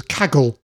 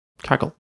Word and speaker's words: Kaggle: But the big revelation to me was Kaggle. – Kaggle. – Which is spelt K-A-G-G-L-E.